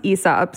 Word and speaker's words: Aesop: And they're also, it's apparently pronounced Aesop.